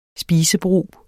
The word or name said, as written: spisebrug